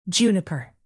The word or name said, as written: juniper